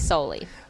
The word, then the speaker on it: Soule: we've been pronouncing his name wrong this whole time. – Apparently it's Sol.